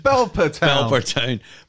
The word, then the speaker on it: Belper Town: It chucks up games like Notts County versus Bleeper Town, or however it's pronounced. – Belper Town.